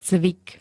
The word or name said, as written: cvik